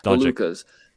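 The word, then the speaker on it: Dončić: It's cool seeing the Giannis's and the Doncic's, or however the hell you say his name. – Doncic's. – There you go.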